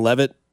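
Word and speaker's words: Leavitt: Are Sam Levitt from Arizona State. I think that's how you say his name.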